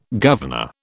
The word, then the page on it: governor: British English